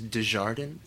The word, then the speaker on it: DeJardin: Very American version is DeJardin.